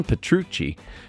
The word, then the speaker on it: Petrucci: John Petrucci, he unveiled Rock the Barrel, a signature bourbon whiskey made in collaboration with Iron Smoke Distillery.